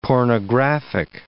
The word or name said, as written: pornographic